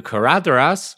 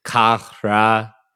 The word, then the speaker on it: Caradhras: Caradhras is one of the weirder words in the books and also in the films, because the DH letter combination in Sindarin kind of has like a TH kind of TH sound, like, I can't describe it well, which is why I wrote this batshit pronunciation guide.